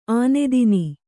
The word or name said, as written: ಆನೆದಿನ್